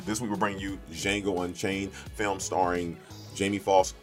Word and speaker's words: Django: Django is the wrong interpretation of it. It is Django Unchained, the D is silent, filmed by the great Quentin Tarantino.